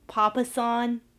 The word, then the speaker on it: Papasan: Yeah, I think they call it a, and I'm totally going to butcher this name, Papasan? Is that what they call it? – Oh, yes, you are correct.